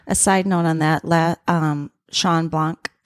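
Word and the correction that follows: Bloch: Am I saying his name right? – Block. B-L-O-C-H.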